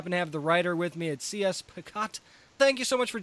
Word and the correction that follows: Pacat: Please tell me I pronounced that right because I can't tell you how many times I, I butcher names on this show. it's pronounced Paquette, but um, Paquette.